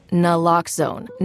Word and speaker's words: Naloxone: For the record, it's Naloxone. Nuh, like, is Naloxone addictive? Nuh-uh. Lox, like Naloxone keeps humans safe, like locks keep bikes safe.